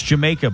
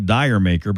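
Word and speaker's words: D'yer Mak'er: And that song is pronounced Jamaica, by the way. If you read the title, it looks like Dire Maker, but that's a play on how you would say it with a British accent.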